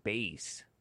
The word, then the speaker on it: Bass: Burton, author of Black Gun, Silver Star, explicitly clarify his name is pronounced like bass.